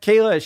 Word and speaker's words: Kayla: bow, We also have a Project for Awesome message from James to Kayla, rhymes with Venezuela. I want to give a shout out to my wife, Kayla.